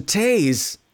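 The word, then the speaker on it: Taix: It's spelled T-A-I-X. I don't know how you would say that, but we always called it Taze.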